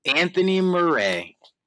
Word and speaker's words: Anthony Meray: Anthony Meray.